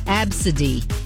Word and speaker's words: Abcde: This person's name was A B C D E, pronounced Absidy.